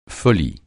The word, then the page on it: folie: francais